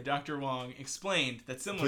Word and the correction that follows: Wang: Please pronounce it correctly. – Dr. Wang explained that similar cases were common amongst children who are curious, in quotes, and still getting to know their bodies.